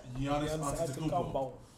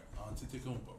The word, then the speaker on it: Antetokounmpo: Giannis Antetokounmpo. Yeah, say his name right. Antetokounmpo.